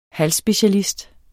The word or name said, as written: halsspecialist